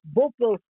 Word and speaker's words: Beauport: Et puis, euh, si vous voulez un peu vivre les folies de Beauport, il faut prononcer Beauport, Beauport Folies.